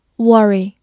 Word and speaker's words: worry: worry, w o r r y, worry, w 发 w，字母 o 发短音 a，r r y 发 r，worry, worry，或者在美式的发音中把 o r 看作一个字母组合，读作 er 这个长音，worry, worry 也是可以的。